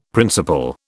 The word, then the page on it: principal: British English